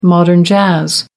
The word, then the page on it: modern jazz: American English